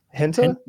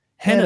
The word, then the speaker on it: henna: Henna.